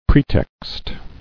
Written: Pretext

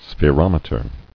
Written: Spherometer

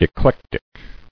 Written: Eclectic

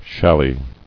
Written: Challis

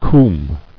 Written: Combe

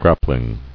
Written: Grappling

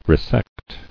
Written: Resecting

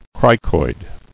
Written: Cricoid

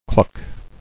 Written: Cluck

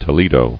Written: Toledo

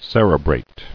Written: Cerebration

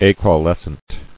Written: Acaulous

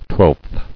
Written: Twelfth

